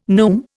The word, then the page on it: neume: Indian